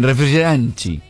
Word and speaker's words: refrigerante: ¿Cómo se dice gaseosa? – Refrigerato. – Bien, refrigeranchi. – Refrigeranchi. – Refrigeranchi. – Como el líquido refrigeranchi.